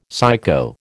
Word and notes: psycho: American English

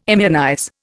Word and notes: immunize: Indian

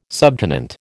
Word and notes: subtenant: American English

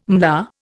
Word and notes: MLA: Indian

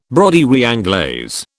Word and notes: broderie anglaise: British English

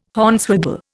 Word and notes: Hornswoggle: Indian

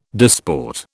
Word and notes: disport: British English

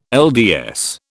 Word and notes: LDS: British English

